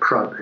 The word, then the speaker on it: Prudhoe: That's like Prud, it's spelled Prudhoe, which is like Norman for Proud Heights.